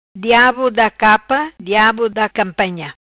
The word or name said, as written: Diabo da capa